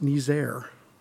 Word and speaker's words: Niger: It is pronounced Niger. I did not know that.